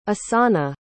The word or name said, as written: osana